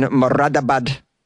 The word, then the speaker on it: Moradabad: All right, after finishing their shift at the export firm based in Moradabad in Uttar Pradesh, that was a perfect pronunciation.